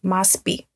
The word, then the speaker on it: MASP: On episodes 37 and 38, I mentioned one of Lena Bobardi's masterpiece, Sao Paulo Museum of Art, and I referred to it as MASP. And after the episode aired, a listener commented that the correct pronunciation would be MASPI.